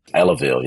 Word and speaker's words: Ellaville: You grew up in a town called Ellaville, Georgia. Am I saying that right? – Ellaville, yep. That's correct.